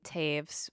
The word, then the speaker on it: Toews: by a writer named Miriam Taves, which is spelled T-O-E-W-S.